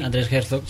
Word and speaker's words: Herzog: Bueno, hablábamos antes con el candidato de UPyD, de Herzogov, me parece que se pronuncia así. – Andrés Herzog, sí.